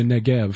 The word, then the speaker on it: Negev: David would say, "Against the Negeb of Judah." Or against the Negeb of Jerahmeelites. – And that'd be the Negev. Mm-hmm. This is one of those bees that's pronounced like a...